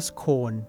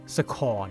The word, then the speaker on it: scone: ถ้าเขียนว่า c o n e ก็คือต้องอ่านว่าโคนแล้วพอใส่ s เข้าไปเนี่ยก็ต้องอ่านว่าสโคนไม่ใช่อ่านว่าสคอน